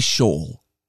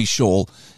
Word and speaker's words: Scholl: If you're not familiar with Sophie Scholl, you'll have to find a copy of Sophie Scholl, The Final Days. Her last name is spelled S-C-H-O-L-L. Very similar to school, but it's spelled Scholl.